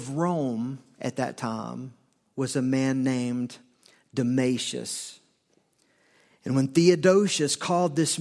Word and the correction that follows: Damasus: meeting of the bishops together to reaffirm what was agreed on at Nicaea, he did not invite Damasus. That's how his name is pronounced. I may have said that wrong, but it's Damasus. He only invited the bishops in the Eastern Empire, not the Western Empire.